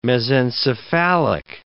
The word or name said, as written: mesencephalic